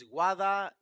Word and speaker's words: Guadalajara: There is a L after the second A. Before the third A. – Oh, Guadalajara. – Guadalajara. – I never pronounced it Guadalajara. – It's Guadalajara.